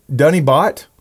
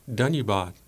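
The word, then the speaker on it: Dunnybot (धन्यवाद): Is that, how do I say that? – Dunnybot. – Dunnybot? – Dunnybot. – Thank you in Nepali. – Oh, no. So, but what is it? – Dunnybot. – Dunnybot. – Dunnybot. B-A-D.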